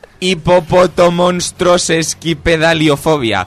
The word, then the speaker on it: Hipopotomonstrosesquipedaliofobia: Hipopotomonstrosesquipedaliofobia. – Repite. – Hipopotomonstrosesquipedaliofobia. – Señor Cine, repita. – Espérate. – Señor Cine, repita. – Hipopotomonstroesquipedaliofobia. – No, es sesquipedaliofobia, no esquipedalifobia.